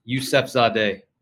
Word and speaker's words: Yusefzadeh: Pretty close. Pretty close. It's Yusefzadeh.